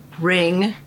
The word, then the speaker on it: Ring: Okay, I'm Donna Marie Ring Craighead.